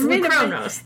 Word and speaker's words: Lukronos: It's spelled L-U-K-R-O-N-O-S. It's Lou Kronos.